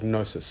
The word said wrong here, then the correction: gnosis: sorry, I pronounced that wrong. The Greek is gnosis.